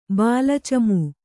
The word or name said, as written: ಬಾಲಚಮೂ